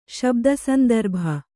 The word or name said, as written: ಶಬ್ದಸಂದರ್ಭ